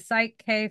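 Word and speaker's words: PSYCH-K: Now, I know you're a holistic health practitioner, and is it Psych K facilitator? Am I saying that correctly? – Yes.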